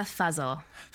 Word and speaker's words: Fazal: Zehra Fazal. Did I say that right? – Zehra Fazal. – Fazal. – You said it prettier. – I said it prettier. So I'm speaking with Zehra. – Yeah, Fuzzle's kind of like nothing.